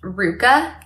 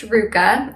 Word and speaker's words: RVCA: It's actually pronounced Ruka. If you're an OG, you would know that. So I know a lot of people know it by RVCA, but it is pronounced Ruka.